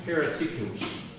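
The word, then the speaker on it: heretikos (αἱρετικός): The word in Greek here is heretikos.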